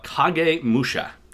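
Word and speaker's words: Kagemusha: This week, though, we are talking about Kage Musha. Did I say that right? – You did great.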